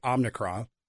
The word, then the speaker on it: Omicron: First off, there isn't a politician in this land that can say Omicron. They can't say it right. It always comes out Omni.